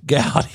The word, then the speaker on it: Gowdy: I'd like to bring up Howard Wilson Trey Godey. Did I say it right? – Gowdy. – That's what I said. Gowdy.